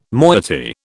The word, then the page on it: moiety: British English